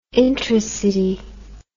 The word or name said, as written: intracity